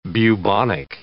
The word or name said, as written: bubonic